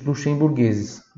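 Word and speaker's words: lussemburghese: Se escreve L-U-S-S-E-M-B-U-R de rato. Tem aqui nosso G-H-E-S-E. Luxemburguese. Ficou claro? Então você não pode esquecer. Sempre quando tem G-H, tem som de G. G. Se é G-H-E, G. G-H-I, Gui, tá bom?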